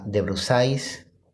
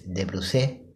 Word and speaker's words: de Brusais: Hoy les quiero presentar a María Fernanda de Brusais, o como se pronuncia correctamente en francés, de Brusé.